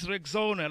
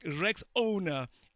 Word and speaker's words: Rexona: But guys, this is Rexona, like Rex owner. It's Rexona. This new name, guys, comes with a fresh new look and advanced body heat technology.